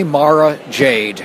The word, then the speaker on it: Mara Jade: It's officially Mara Jade.